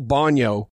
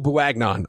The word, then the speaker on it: Bouagnon: I think Mays is in, and I think Joel Bonio, and I'm going to call him Joel Bouagnon, and I know that's not how you pronounce it, but that's I've been reading about him, and it was like, well, it's Bouagnon. I mean, it looks like Bouagnon, but it's not. It's Joel Bonio.